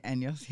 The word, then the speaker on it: Anyos: Did I pronounce that right? – Anyos.